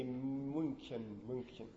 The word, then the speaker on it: München: And don't try to ask a German, an English speaker to say München, right? There's no sh. And there's no U. Right? That's why it's Munich. Because the same phonemes just don't exist.